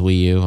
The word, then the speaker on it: Wii U: Yeah, Boohoo rhymes with Wii U, I know.